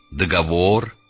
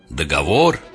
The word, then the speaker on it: договор: Я об ударении. Договор, некролог, каталог. Я сознательно чуть-чуть утрированно сделал правильное ударение. Договор, некролог, каталог.